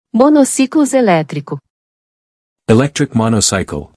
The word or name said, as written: Monociclo eletrico